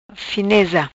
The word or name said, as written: Fineza